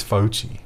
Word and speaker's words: Fauci: White House advisor Dr. Fauci, did I say that correctly? – I think it's Fauci. – Fauci says that handshaking needs to stop even when whatever this is that we're in ends and other experts agree.